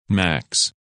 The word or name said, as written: Max